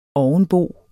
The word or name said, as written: ovenbo